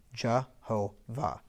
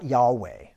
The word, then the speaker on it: Jehovah: So if you put vowels in between there, and the Y is pronounced as a J, and the W is pronounced as a V, Jehovah. That's how you pronounce God's name. It's not Yahweh, right? Yahweh is two syllables, right?